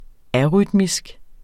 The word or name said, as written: arytmisk